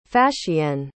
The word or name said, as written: fascienne